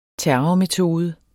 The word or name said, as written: terrormetode